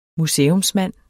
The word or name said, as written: museumsmand